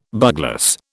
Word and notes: bugloss: British English

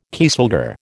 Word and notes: kieselguhr: American English

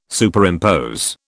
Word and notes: Superimpose: British English

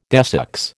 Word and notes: desex: American English

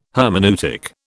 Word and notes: hermeneutic: British English

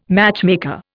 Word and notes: matchmaker: Indian

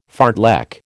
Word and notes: fartlek: American English

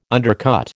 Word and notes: undercut: American English

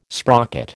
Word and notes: sprocket: American English

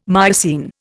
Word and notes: Miocene: Indian

